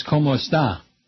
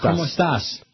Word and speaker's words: como estás: Whatever else you heard, como está, is plural, like if you're talking to a group. Or como están, something like that.